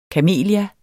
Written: kamelia

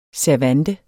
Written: servante

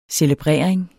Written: CELEBRERE

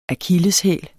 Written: akilleshæl

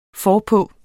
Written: forpå

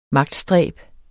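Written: MAGTSTRÆB

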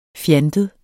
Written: fjantet

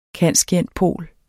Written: cand.scient.pol.

